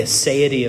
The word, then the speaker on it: aseity: But the one that I want to camp out on with the time we've got left is the aseity of God. Aseity. It is spelled A-S-E-I-T-Y.